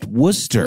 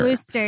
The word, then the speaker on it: Worcester: That city in Massachusetts you referenced is pronounced Worcester. – Worcester, you're so right.